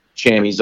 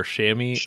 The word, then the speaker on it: chamois: Chamois or chamois, or is it both? – Chamois is the name, the French name, but most hunters call them chamois.